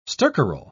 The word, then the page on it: stercoral: British English